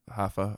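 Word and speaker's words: Rafael: It's spelled like Raphael, but it's Hafa. I don't know if we always just called him Hafa, because I guess the R's are pronounced like H's, and then Parente.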